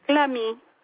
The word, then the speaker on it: Clamy: Clamy.